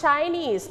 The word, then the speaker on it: Chinese: Not Chinese, but Chinese. Very good. We stayed on the second syllable